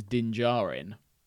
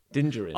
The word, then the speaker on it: Din Djarin: But, like, the Mandalorian's name is Din Djarin. – Din Djarin. – Okay, so it's either two syllables and one, or one syllable then two.